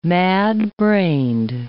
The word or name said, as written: mad-brained